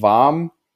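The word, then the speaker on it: vaam: Dann nutzen wir noch Warm.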